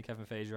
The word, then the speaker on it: Feige: So first, Kevin Feige, as you like to call him, because you know personally, Kevin Feige rhymes with beige.